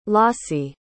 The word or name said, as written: Lossie